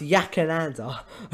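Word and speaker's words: Yackandandah: It's pronounced Yakananda.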